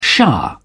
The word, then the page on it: shah: British English